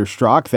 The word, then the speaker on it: Strzok: Now, people are hearing that I'm interviewing Peter Strzok. They, well, first of all, might say, oh, that's how you pronounce it. I was wondering what the Z was doing there.